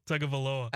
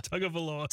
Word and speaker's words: Tagovailoa: Tagovailoa. – I don't think Chad said it right. On the Tagovailoa.